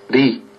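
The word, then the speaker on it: Li (리): et en Corée du Nord, il se prononce Li.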